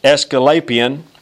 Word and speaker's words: Asclepius: And the other one is pronounced Asclepius.